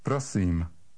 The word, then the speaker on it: prosím: Number 17 has a long I. It sounds almost like the double E in the English word feed. Read number 17. – Prosím.